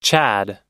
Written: Chad

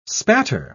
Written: spatter

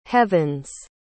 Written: heavens

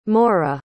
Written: moreh

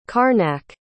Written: karnak

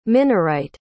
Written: minorite

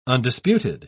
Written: undisputed

